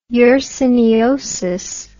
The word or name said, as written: yersiniosis